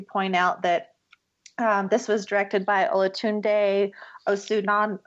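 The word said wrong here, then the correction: Osunsanmi: Oh, let me say that again. Olatunde Osunsami. Ooh, I said it right.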